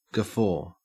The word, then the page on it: guffaw: Australian English